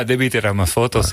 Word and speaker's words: Debí tirar más fotos: Debí tirar más fotos. Ja. Zeg ik het goed? – Ja, debí tirar más fotos. Ja, zeker.